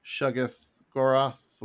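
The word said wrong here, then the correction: Shuma-Gorath: however you say his name, Shuggoroth, whatever. – Shumagoroth, there it is.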